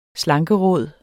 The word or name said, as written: slankeråd